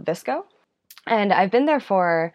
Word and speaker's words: VSCO: Yeah, so Visco, which is spelled V-S-C-O, we pronounce it Visco, but you can pronounce it however you want.